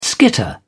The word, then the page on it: skitter: British English